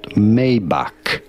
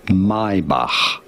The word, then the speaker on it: Maybach: No, it is certainly not Maybach. The CH letters in German are to be pronounced as a H sound. H. So it is not Maybach either, but rather Maybach.